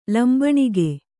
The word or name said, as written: ಲಂಬಣಿಗೆ